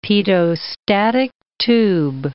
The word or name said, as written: pitot-static tube